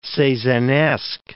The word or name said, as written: Cezannesque